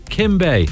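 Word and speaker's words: Keem: How do you pronounce it? – Kim. – Kim Bay.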